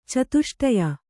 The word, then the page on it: ಚತುಷ್ಟಯ: catuṣṭaya